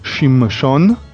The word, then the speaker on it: Shimshon: So it's called Shimshon.